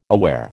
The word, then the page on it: aware: American English